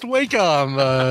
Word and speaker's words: Wacom: I think it's pronounced Wacom, Alex.